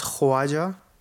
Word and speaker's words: Khawaja: It's pronounced Khwaja.